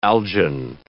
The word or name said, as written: algin